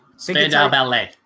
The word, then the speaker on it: Spandau Ballet: Is it Spandau Ballet? – Spandau Ballet.